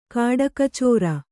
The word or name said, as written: ಕಾಡಖಚೋರ